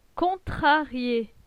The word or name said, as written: Contrarie